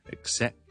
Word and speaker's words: accept: 是一定要读埋个 K 音嘅，但个 K 音亦都系一样啦。 K 音连埋 S 音一齐读，accent accent accent。如果就咁读成 accent 咁就唔得，OK？一定要 K accent accept。